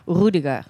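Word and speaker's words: Rüdiger: Like, in German, you would pronounce it Rudiger.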